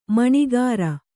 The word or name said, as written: ಮಣೆಗಾರ